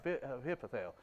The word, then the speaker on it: Ahithophel: Ahithophel, yeah. Is that how you say it? Yeah, Ahithophel.